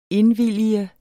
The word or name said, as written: indvillige